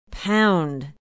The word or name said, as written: Pound